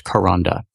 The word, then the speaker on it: Kronda: This is a blog post by Karanda Adair that's spelled K-R-O-N-D-A. It's pronounced Karanda, and it's called 5 Stages of Unlearning Racism.